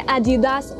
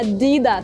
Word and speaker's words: Adidas: Так вот, правильно говорить не Adidas, а Adidas.